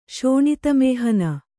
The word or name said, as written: ಶೋಣಿತಮೇಹನ